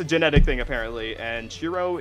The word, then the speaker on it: Shiro: Is it Shiro? – It is Shiro.